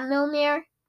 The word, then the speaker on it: Mjolnir: Yeah, Mjolnir. I don't think I said that right. – You said it right.